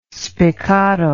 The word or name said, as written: spiccato